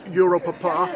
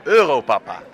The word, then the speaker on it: Europapa: Europapa, I'm pronouncing it wrong. How do you say it? – Europapa.